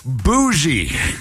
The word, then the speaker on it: bougie: Bougie.